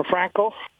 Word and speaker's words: Franchi: Or Franco, F-R-A-N-C-H-I. It's pronounced different than what it's spelled.